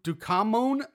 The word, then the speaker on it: Ducommun: Ducommun.